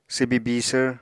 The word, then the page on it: swbi bisvr: Apatani